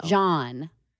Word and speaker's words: jawn: J-A-W-N. Like yawn, but with a J in front of it. – Oh. – John.